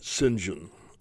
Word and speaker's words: St. John: It's spelled St. John Hankin, but, you know, in that British upper-class way, they pronounce it St. John. So St. John Hankin.